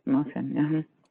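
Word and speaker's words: Marsanne: Is it Marsan? I don't know how to pronounce this. You know how I am on French. – It's Marsan.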